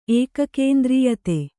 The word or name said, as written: ಏಕಕೇಂದ್ರೀಯತೆ